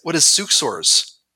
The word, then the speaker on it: suxors: If someone not from our clan tried to read that, they might not say, well, what is suksors? Which is how you would pronounce S-U-X-O-R-S versus saying they suck with an X.